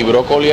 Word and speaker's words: Broccoli: C-U-B-B-Y. – Ok, y brócoli es como el... Brócoli, como el brócoli.